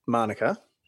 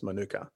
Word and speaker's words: Manuka: In a historic first for Australia, the beer rights for Canberra's, now the Canberrans, pronounce it Manuka. – Yep. – It's a Maori word, so it's Manuka. Oval. – I always heard Manuka, like the honey.